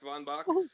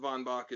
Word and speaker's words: Von Bach: That is correct. Von Bach. Some people go Von Bach to be particularly Prussian, but Von Bach is what people say.